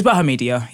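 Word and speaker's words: Bahamadia: Bahamedia? Let me check. It's Bahamedia. Yeah, that's how you say it.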